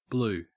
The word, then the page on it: blue: Australian English